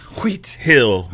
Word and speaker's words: Wheat Hill: You didn't even say it right. – What? – I said you didn't say it right. Weak Hill Mobile Home Community? – Yeah, you got to say it like Steve Harvey. – Oh. – Wheat Hill Mobile Home.